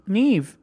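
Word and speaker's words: Niamh: Neem. – Oh. Neve. – Neve. – Yeah. – Neve. No V or H? Why would you pronounce an M like a V? – That's just how...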